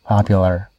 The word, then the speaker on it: popular: I just noticed that you can pronounce popular without sticking out your tongue. Popular. But it's more natural for me if the tongue comes here.